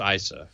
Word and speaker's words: Issa: I had no idea Daryl Issa Is it Issa or Issa? – I've heard it pronounced Issa.